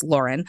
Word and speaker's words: Lawrin: So, you know, then in the story of Lauren and how he ended up there, and it's spelled Lawrin, so it's kind of like Lauren, but it's Lauren,